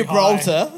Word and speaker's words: Gibraltar: They go off the ship and they go explore Gibraltar, British Overseas Territory. – Hang on. What? Where do they explore? – How do you say that? I went to Modbury Gibraltar. – High. Gibraltar.